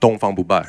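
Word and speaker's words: Dongfang Bubai (東方不敗): Um, so on Invincible East, the cover depicts Dongfeng um, Bubai. – right, Dongfang Bubai.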